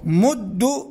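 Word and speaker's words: مُدُّ: وأيضا في مد يمد صيغة الأمر تقول مد، وربما هنا يلتبس مد، يظن أحد أنه خطاب لجماعة الذكور، لكن لا، طريقة النطق مد تلفظ ضمة، أما جماعة الذكور يقال مدوا بحركتين، أما هنا بحركة واحدة.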